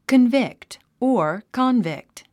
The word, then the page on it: convict: kənvíkt; カンビクト